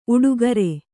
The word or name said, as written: ಊಟಗಾರ